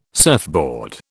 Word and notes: surfboard: British English

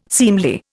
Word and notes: seemly: Indian

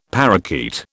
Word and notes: parakeet: British English

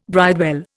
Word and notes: bridewell: Indian

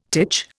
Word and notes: titch: Indian

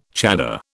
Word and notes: chador: British English

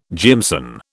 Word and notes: jimson: British English

